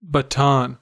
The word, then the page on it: baton: American English